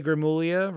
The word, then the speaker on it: Gramuglia: Yeah, A G R A M U G L I A. Silent G uh, at Twitter. – Yes. Well, it's weird. Italian names are strange. It's like it's like a like GLIA is like a kind of a silent sound, but it's like a it's weird. Italian's weird.